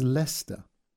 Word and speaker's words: Leicester: It's written as Leicester. He enunciated Leicester and then said Leicester slowly and sharply. But it's pronounced as Leicester. A sharp-faced woman replied, But why? Young language guy. Oh, I think it's something to do with the French.